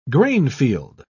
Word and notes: grainfield: British English

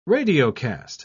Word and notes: radiocast: British English

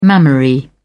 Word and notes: mammary: British English

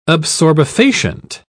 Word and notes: absorbefacient: British English

